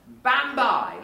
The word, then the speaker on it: bambae: The future, if it's in the future, then it's bambai, like by and by, really. B-A-M-B-A-E is how it's spelt in the Vanuatu spelling system. B-A-M-B-A-E, bambai is the future.